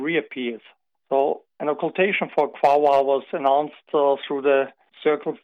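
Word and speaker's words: Quaoar: So in terms that we can understand, you found a ring around this planet Quawar. Is that how you pronounce it? – Quawar.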